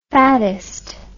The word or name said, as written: fatism